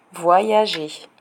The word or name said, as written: voyager